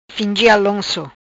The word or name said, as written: Fingi Alonco